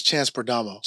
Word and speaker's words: Chance Perdomo: And like, just too, for the, what you had said, his name is Chance Perdomo. – Chance Perdomo. – I didn't want to say his name wrong, so I looked it up before.